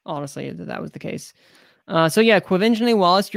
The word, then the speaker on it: Quvenzhané Wallace: It's Quvenzhané Wallace. I know that one. – Quvenzhané Wallace.